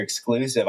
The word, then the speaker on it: XCLSV: I didn't even think of it that way, actually, because I that's another story for it, but that G Major Exclusive, I thought I was pronouncing it XCLSV.